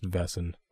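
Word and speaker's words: Wesen: Now, these people that can transform are called Vessen, and it's spelled with a W, but pronounced Vessen.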